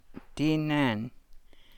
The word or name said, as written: dii naan